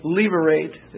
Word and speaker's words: Levirate: Probably Levirate would be the way to pronounce it. L-E-V-I-R-A-T-E. Levirate marriage. The word lever, L-E-V-I-R, that's a Latin word meaning husband's brother. L-E-V-I-R.